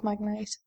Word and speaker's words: magnate: Magnate, however you pronounce it. – Magnet. – Magnet sounds better. I swear it's pronounced magnet, but... – I'm sure it's magnate, but. Still, that sounds wrong. – Magnate. – Shut up, you. Magneto. Thank you, for the correction.